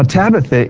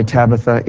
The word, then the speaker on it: Tabitha: Now, Tabitha is the Syro-Chaldaic way to say her name.